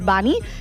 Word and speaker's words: Bunny: La pronunciació correcta en anglès és Bunny. Ell vol que li diguem Bunny.